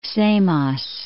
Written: Samos

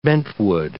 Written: bentwood

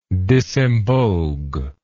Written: disemboguement

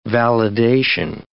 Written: validation

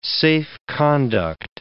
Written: safe-conduct

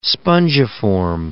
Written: spongiform encephalopathy

